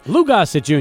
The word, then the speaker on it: Lou Gossett Jr.: I might be saying his name wrong, Luis Gossett Jr. – Lou Gossett Jr., yes.